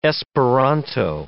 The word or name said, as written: Esperanto